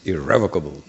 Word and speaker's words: irrevocable: Frequently devotees read it irrevocable, but Dravida Prabhu said that the proper, or was it Jayadvaita? I think Dravida Prabhu said that the proper pronunciation is the emphasis, the stress is irrevocable.